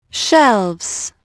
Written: shelves

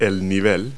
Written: level

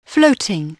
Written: floating

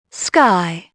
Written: sky

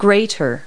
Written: grater

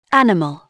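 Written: animal

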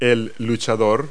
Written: wrestler

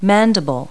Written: mandible

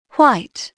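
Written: white

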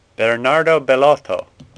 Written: Bellotto

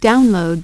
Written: download

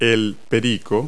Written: parakeet

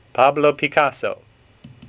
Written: Picasso

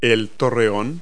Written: turret

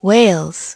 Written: whales

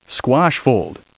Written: squash

